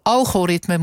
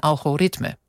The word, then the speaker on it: algoritme: Nee, maar ze hebben het wel verstaan, alleen ik had de klemtoon verkeerd, Jurgen. Want algoritme moet zijn algoritme.